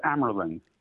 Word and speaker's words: Amerling: That's close. It's Amerling.